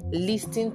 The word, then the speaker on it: listen: And another one, listen to me. Are you listening to me? Please, nobody's listening to you because the T is silent.